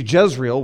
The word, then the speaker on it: Jezreel: See, Jezreel, which, by the way, almost sounds like Israel. The J's in Hebrew are actually pronounced with a Y, so it would be Jezreel. Jezreel was a city where King Ahab had his palace.